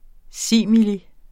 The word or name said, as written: simili